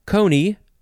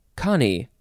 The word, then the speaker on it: coney: Next word is coney or connie. Connie? Yeah, it is spelled C-O-N-E-Y, or you can take out the E. I'll just say coney.